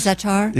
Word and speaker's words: za'atar: But the grape leaves and the za'atar, is that what it is? – Za'atar? – Yeah, za'atar. Right. Is that how you say it? – Yes.